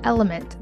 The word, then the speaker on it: LMNT: This podcast is sponsored by Element. That's spelled L-M-N-T.